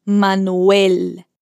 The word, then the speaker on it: Manuel: Então repete o nome dele aqui comigo e atenção com a pronúncia do E bem fechado e desse L que a sua língua fica lá em cima. Manuel.